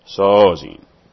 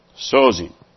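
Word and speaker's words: sozein: The word salvation is S-O-Z-E-I-N. S-O-Z-E-I-N. Sozin. Sozin. It's the accent is on the first syllable. Sozin.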